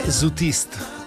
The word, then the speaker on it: Zutistes: La definizione che io ho letto in bolognese tra gli zutiste è zutiste. Ecco, l'ho detto meglio, in effetti è francese, però il mio francese ha solo la R, che è una malformazione della pronuncia più che altro che un aspetto francofono.